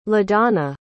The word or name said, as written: ladonna